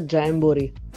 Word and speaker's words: Jamboree: Si pronuncia Jamboree, però Jamboree ci piace lo stesso. In effetti, non avendo specificato, non è così chiara la pronuncia, però va bene.